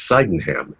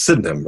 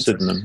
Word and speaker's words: Sydenham: And I always mispronounced it as Sydenham. That's not correct. Yeah. It's Sydenham, right? – Sydenham, yeah. – So it's Sydenham.